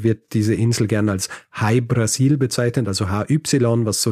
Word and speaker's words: Hy Brasil: Jahrhundert jetzt, wenn in Irland drüber geschrieben wird, dann wird gerne mal die Insel zum Beispiel Breasil geschrieben oder O Apostroph Brasil oder eben Ui Breaseil, was ausgesprochen quasi so viel wie High Brasil ist.